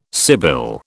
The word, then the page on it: sibyl: British English